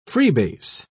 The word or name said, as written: freebase